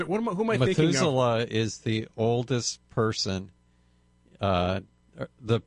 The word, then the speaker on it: Methuselah: How do you say it? – Methuselah.